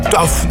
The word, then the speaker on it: tough: Vroeger wist ik nooit hoe je het moest uitspreken. Tough dus inderdaad, ja.